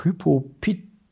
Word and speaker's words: Hypopituitarismus: Vom Hypopituitarismus. Genau, Hypopituitarismus.